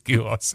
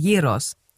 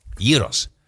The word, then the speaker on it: Gyros: Und dann hat er mir gesagt, oder heißt es, du sagst Gyro, ne? Mein Mann, das S spricht man immer nicht. Ne? – Nein, es ist im Griechischen, es ist Gyros. – Gyros? – Ja.